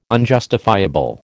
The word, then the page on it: unjustifiable: American English